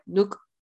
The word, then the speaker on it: Nuch: It's spelled N-U-C-H, but they pronounce it kind of like Nook.